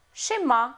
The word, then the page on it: schéma: French